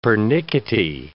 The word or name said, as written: pernickety